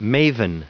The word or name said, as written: maven